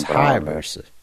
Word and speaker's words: Hayabusa: Fucking Patrick Kabusa. – It's not Kabusa, it's Hayabusa. You've offended a lot of people by mispronouncing his name.